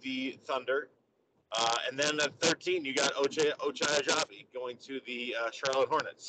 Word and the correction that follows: Agbaji: So, Agbaji. You don't pronounce the G. – Agbaji.